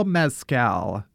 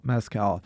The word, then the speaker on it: Mescal: Is it pronounced like the liquor? – I mean, it is now. – Chris Pine versus Paul Mescal.